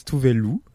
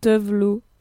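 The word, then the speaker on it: Tove Lo: Donc ça s'écrit T-O-V-E plus loin L-O et ça se prononce Tuvelu.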